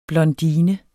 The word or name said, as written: blondine